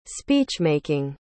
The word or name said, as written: speech-making